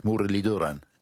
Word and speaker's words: Muralitharan: It is Mudaliduran. – Yes, the th is a d, which is a dental t with, I think, your tongue touching your teeth, Mudaliduran.